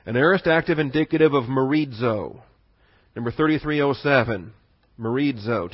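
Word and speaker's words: merizo (μερίζω): Your word there is merizo. M-E-R-I-Z-O. Accent on the iota, accent on the I.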